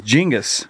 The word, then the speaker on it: Genghis: Genghis? – Yeah, it's Genghis. – It's Genghis. – It's pronounced Genghis.